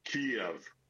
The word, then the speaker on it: Kyiv: it is not as easy as simply saying Kyiv. It's not a single-syllable city. It is Kyiv.